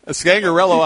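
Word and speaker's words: Scangarello: You know, to hire Rich Scangriola. I got to get you to help me pronounce that. – Scangriello, I believe, but we'll pin that down. – That's it. You got it.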